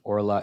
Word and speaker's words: orla: You wouldn't know this, but the kamatz under the ayin of orla is a kamatz katan, so it's pronounced orla.